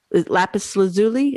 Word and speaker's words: lapis lazuli: Lapis lazuli, is that it? – Yes.